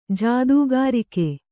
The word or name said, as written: ಜೊತೆಗಾರಿಕೆ